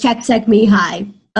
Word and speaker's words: Csikszentmihalyi: The way I would pronounce it is Csikszentmihalyi.